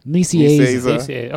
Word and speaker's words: Nicieza: Fabian Nicieza.